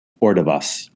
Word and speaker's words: Ordovás: So Susanna Ordovas, is that how you pronounce her last name? – Ordovas.